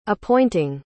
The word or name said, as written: appointing